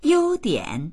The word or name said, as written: you1dian3